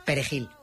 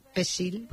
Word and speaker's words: Perejil: Y si decían, en vez de Perejil, decían Pesil, pues, que es como lo llamaban, sí, sí.